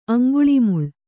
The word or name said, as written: ಅಂಗುಳಿಮುಳ್